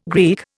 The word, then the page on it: Greek: Indian